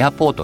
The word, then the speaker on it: アポ: ァベットに略して 3 レター 3 文字で略して何かを事物を表すことがあるんだけどその中の一つで空港のことをアポエアポートを略して APO で「アポって業界では空港のことをアポっ